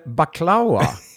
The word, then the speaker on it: baklava: Det heter baklava. Men det är ju fan exakt. Balaklava, baklava. – Ja. – Otroligt svenskt uttalar mig.